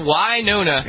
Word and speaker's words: Winona: It's Winona.